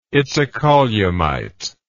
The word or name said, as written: itacolumite